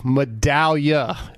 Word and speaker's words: Medaglia: Medaglia.